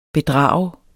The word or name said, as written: bedrager